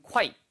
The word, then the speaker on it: quite: 이거를 quiet는 quiet이에요. quiet. 이거는 조용한이란 뜻이고요. quiet이라고 쓰면은요. 그건 quite. 꽤란 뜻입니다. 그러니까 발음 구분하세요. 얘는 quiet. 그 다음에 꽤라고 할 때는 quite, quite good 이렇게 얘기할 때 쓰는 표현이죠.